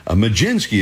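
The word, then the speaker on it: Mlodzinski: And Robbie Ray is up against a pitcher whose name, it doesn't sound like it looks. It's M-L-O-D-Z and a lot of other little vowels and S's and K's in there. Majinski is how he pronounces it.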